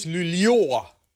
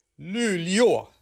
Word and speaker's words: Luleå: Het gaat dus om een plaats die je spelt als L-U-L-E-A met dan een rondje op de A. En zo spreek je dat volgens onze luisteraars echt uit op zijn Zweeds. – Een ringeltje, een kringeltje, een rondje boven een A is een oa. Dus het is Luleå.